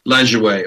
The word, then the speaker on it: Lajoie: right behind Nap Lejo- I can't I don't know how to pronounce his name. Napoleon Lajoie. – Lajoie.